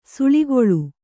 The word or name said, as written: ಸುಳಿಗೊಳ್